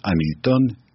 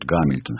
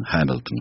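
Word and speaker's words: Hamilton: И вот княжна Тараканова не подозревает, что тот человек, которого по-французски ей называют «Амильтон», что это лорд Гамильтон, Хэмилтон, как его по-настоящему, что он пишется с H, H, он пишется в начале, и она везде в письмах, она пишет «Шевалье Дамильтон», пишет «Д», апостроф, и начинает его фамилию с «А», «Дамильтон».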